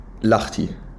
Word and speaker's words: Lahti: Oh, du hast es richtig ausgesprochen. Ich habe Nachrichten bekommen, weil ich Lahti gesagt habe und da gab es richtig viel Hate für. Da gab es so Leute, ich kann das nicht anhören, sag bitte das nächste Mal Lahti.